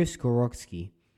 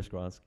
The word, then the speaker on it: Skoronski: So number thirteen, we have the Jets taking Peter Skoronski did I pronounce that wrong? No, you got it. Peter Skronsky, yeah.